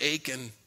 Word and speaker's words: Achan: I love that because I was thinking about Achan, rhymes with bacon.